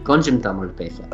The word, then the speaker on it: Konjam Tamil pesa: Konjam Tamil pesa. – You actually said it right.